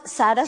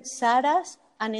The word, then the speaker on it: Saras: It's pronounced Sarasa. – Sarasa. – Yes. But it's written without the last A. So it's written Saras and it's pronounced Sarasa.